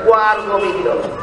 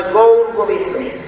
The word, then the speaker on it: গৌর গোবিন্দ: অনেকে গৌর গোবিন্দ উচ্চারণ করতে না ভাই, দেখেন গোয়ার গোবিন্দ, গোয়ার গোবিন্দ নয় গৌর গোবিন্দ।